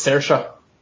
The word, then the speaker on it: Saoirse: Sawsy Rowan, I cannot pronounce her name correctly off the top of my head. – It's Saoirse. – Saoirse.